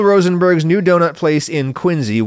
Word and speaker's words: Quincy: Anyway, Bill Rosenberg opened his first donut shop in 1948 in Quincy, Mass., which is pronounced like a Z, Quincy, not Quincy.